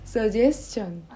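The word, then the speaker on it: suggestion: 아, 네, 루미 엄마 루미한테 하나 태양 가리마스 서게션 어? 서게션 아 루미 그 얘기하지 마 너무 발음이 너무 이상해 서게 걔 아니고 서제스션 아,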